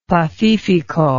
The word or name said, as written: pacifico